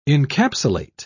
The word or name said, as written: encapsulate